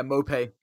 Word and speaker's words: Maupay: Am I saying his name right? Um, Brentford striker. Yeah, Mope. Mope.